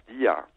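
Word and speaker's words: Dia (Δία): Nee, aber es sind ja zwei Dinge in einem, weil man muss auch wissen, also Zeus wird eigentlich in Griechenland nie als Zeus ausgesprochen oder als Zeus, sondern eigentlich immer als Dia.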